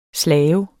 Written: slave

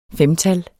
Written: femtal

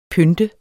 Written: pynte

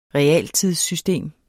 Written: realtidssystem

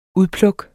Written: UDPLUKKENES

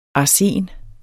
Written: arsen

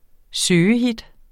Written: søgehit

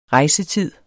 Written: rejsetid

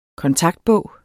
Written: kontaktbog

